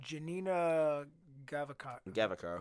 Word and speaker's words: Janina Gavakar: I don't know how to pronounce this. How do you pronounce that? You're looking at that. – Janina Gavakar, who played Aiden Versio.